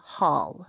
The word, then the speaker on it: hall: 영어로는 홀 이렇게 입술이 동그래지지 않아요. – 그러면 네. 구멍이란 뜻이 돼버리잖아요. – 네. 턱이 그냥 내려가는 느낌으로 hall. – 음흠. – Hall. – 얼에 더 가깝네요.